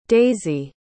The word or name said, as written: daisee